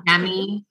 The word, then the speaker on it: Udemy: And actually this week remembered that I had bought this Udemy. Is that how you say it? – Oh yeah. Yeah. Yeah. – Udemy course for meditation, like to get a certification in meditation.